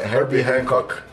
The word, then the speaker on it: Herbie Hancock: Eu não sei falar o nome desse cantor que você falou, é Herbie Hancock. – Eu falei certo, foi ele, né, que é. falou isso, é.